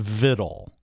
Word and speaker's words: victual: It's, as we said, it's spelled with a C, victual it's spelled as, but it's pronounced, it has been and always has been pronounced vittle. Until, apparently, modern times when people see the word and they pronounce it victual.